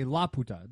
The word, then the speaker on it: Laputa: They've changed the pronunciation, and they say Laputa. They put the emphasis on the first A, not the U, which would completely change the meaning.